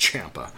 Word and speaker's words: Ciampa: The story continues in a very nice way, and it shows you how good Tommaso Ciampa is, or Ciampa, I think he said on Twitter that it is pronounced Ciampa, but it was excellent.